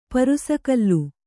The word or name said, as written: ಪರುಸಗಲ್ಲು